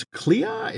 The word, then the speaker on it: Clea: We had Doctor Strange heading off with Charlize Theron's Clea. Is that how we pronounce that name? – Yeah. Yeah. His future wife.